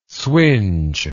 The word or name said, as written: swinge